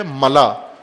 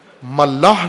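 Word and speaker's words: ملاء: ملا، ملاح نہیں ہے، غلط تلفظ کرتے ہیں بعض، ملا، میم لام الف حمزہ، ملا حمزہ کے ساتھ، خلا جس طرح کہتے ہیں، خلا کہتے ہیں غلط تلفظ میں، خلا، ملا، خلا، ملا۔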